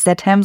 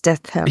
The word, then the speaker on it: Statham: Da ist ein TH drin, klar, aber ich dachte irgendwie, der heißt Statham. Und okay, er heißt nicht Statham, sondern er heißt Statham.